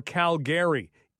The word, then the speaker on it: Calgary: By the way, on a note regarding pronouncing names properly, and somebody had said Calgary or Calgary. It is Calgary. Be careful.